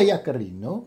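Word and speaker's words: Yaccarino: Oh, it's a hard C. Linda Yacharino.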